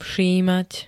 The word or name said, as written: všímať